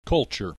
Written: culture

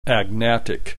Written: agnatic

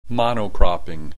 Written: mono cropping

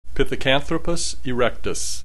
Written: Pithecanthropus erectus

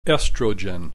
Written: estrogen

